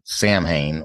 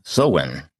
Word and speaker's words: Samhain: And it wouldn't be Halloween if we didn't also examine the association of Stonehenge with its pre-Christian forerunner, the Druid holiday called Samhain, or Samhain, as it is actually pronounced. I'm not sure how you get that from the way the word is spelt,